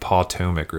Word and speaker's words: Pawtomack: And it's spelled P A W T O M A C K. So it's like Potomac or something like that.